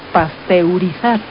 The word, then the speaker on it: pasteurizar: pausterizar. – No, proviene del científico que desarrolló ese proceso, que es Luis Pasteur, que se escribe Pasteur. Entonces el término correcto es pasteurizar. – Entonces no es pausterizar.